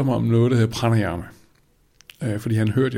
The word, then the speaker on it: pranayama: der hed pranayama. – Prøv lige at udtale det langsomt. – Pranayama. P-R-A-N-A-Y-A-M-A.